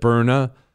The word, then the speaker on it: Byrna: Did you know that Byrna, they sell, it's spelled B-Y-R-N-A.